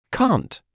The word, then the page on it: cant: British English